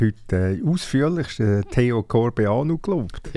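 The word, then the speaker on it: Corbeanu: Es ist Theo, also der Vorname sagt man, äh, spricht man Englisch aus und es ist Corbano.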